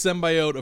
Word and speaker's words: symbiote: So yeah, that's she says it's symbiote. Apparently that is an accepted pronunciation in a lot of the world, but like I said, everything Marvel has pronounced it symbiote since day one.